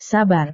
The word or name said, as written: sabar